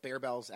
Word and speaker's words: Barebells: Like Grenade, now we've seen barbells come in, or barebells, actually, I think it's actually how Bear you pronounce it, yeah.